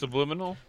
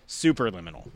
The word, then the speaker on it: Superliminal: Subliminal? – Superliminal.